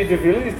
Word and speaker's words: de Villiers: de Villiers, did I pronounce that correctly? – Yeah.